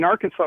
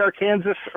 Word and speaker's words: Arkansas: Well, they're both named after the same tribe of Indians, but in Arkansas, it was settled by the French, so it's the French pronunciation. Where in Kansas, it's the English pronunciation. – Huh. – So Arkansas is the French pronunciation? – That's the French pronunciation.